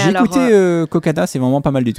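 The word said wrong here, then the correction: Cocanha: Ouais, alors on dit Cocagne. – Cocagne, d'accord. – Ouais, ça s'écrit Cocagna, mais ça se dit Cocagne.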